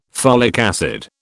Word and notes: folic acid: British English

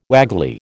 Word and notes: waggly: American English

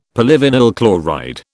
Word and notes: polyvinyl chloride: British English

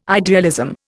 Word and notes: idealism: Indian

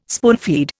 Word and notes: spoonfeed: Indian

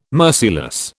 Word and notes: merciless: British English

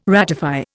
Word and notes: ratify: Indian

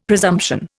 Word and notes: presumption: Indian

